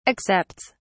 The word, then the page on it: accepts: British English